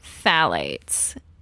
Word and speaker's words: phthalates: So the main hazardous material that's commonly used in sex toys is called phthalates, and that is spelled with a PH. That's PHTHALATES.